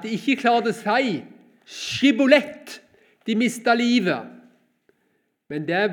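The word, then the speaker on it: Sjibbolet: Da sa de til ham, Si Skibolett. Men han sa, Sibolett, for han kunne ikke uttale ordet riktig.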